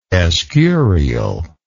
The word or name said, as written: Escurial